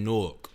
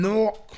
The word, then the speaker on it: Newark: He's from Newark. – Yeah, he's from Jersey. – Newark. – Yeah, he's from Newark. – That's how they say it.